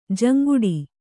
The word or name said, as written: ಜುಂಗುಟ್ಟು